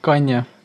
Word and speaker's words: Kanye: Kõigepealt, ma mäletan, sa kuulasid seda Kanye West ja on siis õigesti hääldatud. – Jah, Kanye.